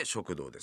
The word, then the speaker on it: 食道: れどもね、えー、食にまつわるあらゆることをテーマにお送りしたいなと思っております、えー、食堂と聞いて思い浮かぶのは学校や会社にある食堂だと思いますけどこの番組は食の道と書いて食堂ですよはい食の道と書いて食堂ですあの内臓器官の食堂ではないですねはい、